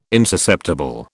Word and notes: insusceptible: British English

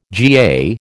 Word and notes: GA: American English

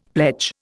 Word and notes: pledge: Indian

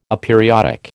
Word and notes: aperiodic: American English

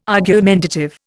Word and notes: argumentative: Indian

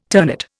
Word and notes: ternate: Indian

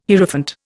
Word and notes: Hierophant: Indian

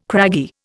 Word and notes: craggy: Indian

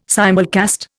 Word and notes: simulcast: Indian

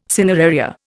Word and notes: cineraria: Indian

